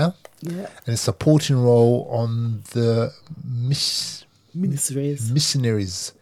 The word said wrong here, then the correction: miniseries: miniseries. mini, sorry, miniseries. Because it's because the, way it's spelled, yeah.